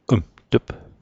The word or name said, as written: mt'p